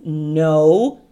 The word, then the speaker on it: know: You know. No, não se pronuncia o K.